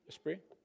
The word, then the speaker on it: Esprit: Isn't that how you pronounce it? Esprit? Yeah. The T is silent.